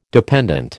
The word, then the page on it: dependent: American English